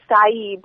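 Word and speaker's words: açaí: a chai or a C or whatever that's pronounced? – No, it's a, I think it's acai, but it's not that.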